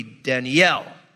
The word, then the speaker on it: Daniel: But with the biblical names, I always tell you this because this is true, that the Semitic languages of Hebrew, in the accent always goes on the last syllable. Okay, that's the big difference. In the Romance languages, the emphasis, the accent's on the first symbol. Like my name, you say Daniel. In Hebrew, it'd be Daniel. Okay, that's the big difference.